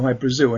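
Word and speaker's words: Hy-Brasil: People pronounce it High Brazil, and it's yeah, Brazil. Okay. – yeah. But it's High Brazil because that's how the Irish would pronounce it, because it's an okay.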